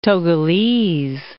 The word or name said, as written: Togolese